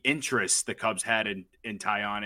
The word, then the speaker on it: Taillon: Jameson Taillon? – That's it.